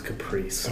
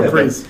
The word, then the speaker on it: caprese: It's pronounced caprese. Oh, jeez. Did you learn that from that show? – no, you just read the word. No, it's the ESE. It's, it's a long E sound. Caprice.